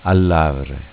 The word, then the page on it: AlLavre: dialetto